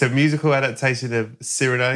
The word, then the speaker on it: Cyrano: Mine is, I picked this also because it wasn't talked about Cyrano. Cyrano, which is how they pronounce it in the film.